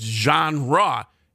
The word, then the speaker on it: genre: And it's like this next one, it's genre. It's pronounced with two syllables, you know. And I can't even mispronounce it. I'm trying to mispronounce it now, but it is genre. And so I can see where that one, because it's spelled G-E-N-R-E.